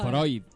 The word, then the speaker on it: Freud: ¿Cómo se dice? ¿Freud o Freud? – No, Freud. – Freud se dice, ¿no? Freud, sí, con D.